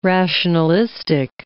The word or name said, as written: rationalistic